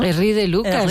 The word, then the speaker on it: Erri de Luca: Erra de Luca, ¿no? – Erri de Luca, sí, y van a ahí en los Alpes, a sí.